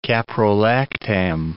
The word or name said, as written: caprolactam